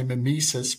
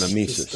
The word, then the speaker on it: mimesis: Mimesis, is how would you say it? – I used to say mimesis, but it's mimesis. Just okay. – Wherever we put the accent.